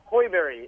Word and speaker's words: Sjöberg: And he says his own name, and he says, Axel Sjöberg. That's as close as I can come to actually pronouncing it the way that he said it. Hoiberry. It's spelled Sjöberg, but he said Hoiberry.